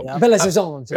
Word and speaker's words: Belo Horizonte: Belo Horizonte.